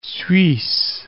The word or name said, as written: Suisse